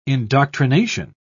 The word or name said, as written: indoctrination